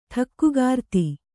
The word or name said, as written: ಠಕ್ಕುಗಾರ್ತಿ